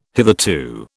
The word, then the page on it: hitherto: British English